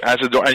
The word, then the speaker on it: Asadorian: Asadorian. And you said it correctly, too. Oh. And it's nice. It's nice hearing it over here because it sounds nice over here. But in the States, it sounds Asadorian.